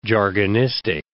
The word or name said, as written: jargonistic